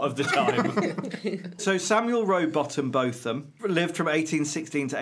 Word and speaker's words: Rowbotham: I would imagine there are people today that will try and pronounce it Rowbotham, but traditionally that was always Rowbottom.